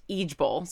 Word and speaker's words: IJBOL: IJBOL. Which stands for, or it's pronounced Ijbol. Sorry, Ijbol. I just burst out laughing.